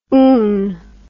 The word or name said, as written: oon